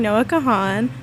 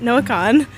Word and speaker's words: Noah Kahan: This next song coming up is Sarah's Place, which is Zach Bryan featuring Noah Kahan. This is Noah Kahan. Sorry for my mispronunciation.